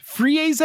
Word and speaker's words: frieze: Ah, the Google tells me it's frieze is the pronunciation.